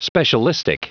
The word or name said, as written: specialistic